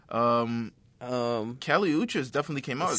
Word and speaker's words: Kali Uchis: Kali Uchis.